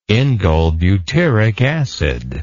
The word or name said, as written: indolebutyric acid